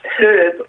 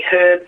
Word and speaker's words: herb: Why do Americans not pronunciate H as in herb? We say herb, and they say herb. – Yep. – I was listening to a speaker once, and he kept an American speaker, and he kept saying herb, and I thought there was something wrong. He had something wrong, but I've since found out that all Americans say herb. – They do, yep. Okay, well, these are words that we've borrowed from French. And in French, you don't pronounce the H. And, for example, in honour, H-O-N-O-U-R, we don't pronounce the H either.